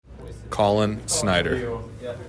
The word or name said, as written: Collin Snider